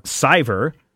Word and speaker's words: Sciver: This past week, speaking of stolen artwork, Ethan Van Sciver, or however Matthew says that we should be pronouncing it. – Sciver